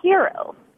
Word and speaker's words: gyro: Well, I used to say gyro, but I had a TA one time from Greece, and she said it was hero.